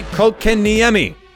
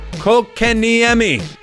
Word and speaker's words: Kotkaniemi: His name is Jesperi Kotkaniemi. Say it with me. Kotkaniemi.